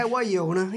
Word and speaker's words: Guaglione: Is that how you pronounce it there, my friend? – Yeah, Waione. Yeah, you're close.